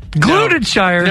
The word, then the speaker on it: Gloucestershire: 200-year-old tradition, the annual Cheese Rolling Festival in Glutenshire. – Gloucestershire. – No, it's called Gloucestershire.